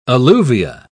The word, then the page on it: alluvia: British English